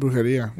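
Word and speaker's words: Brujería: Yeah, like she's was it Brujeria? Is how you say it? – Brujeria. – Yeah.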